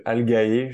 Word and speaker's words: Algae: Aujourd'hui, j'ai le plaisir d'accueillir cofondateur de Algae. Je ne sais pas si je prononce bien. si, tu prononces bien.